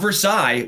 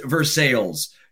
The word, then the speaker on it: Versailles: Going on a bourbon tour down there, not Versailles, which I would have thought is how it was pronounced, but Versailles. Apparently it's pronounced Versailles.